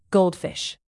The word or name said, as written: goldfish